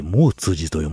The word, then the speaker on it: 毛越寺: 予定よりかなり早めに出まして、でも盛岡行きの新幹線に乗るまで全然時間あったんで、一ノ関駅へ向かう途中、毛通寺があったんで、そこに寄りましてね。毛越寺と書いて、毛通寺と読ませるお寺。